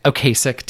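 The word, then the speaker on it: Ocasek: Akasek. I looked up the pronunciation. Okasek? Okasek, damn it. Yeah. I looked up the pronunciation and still did it wrong.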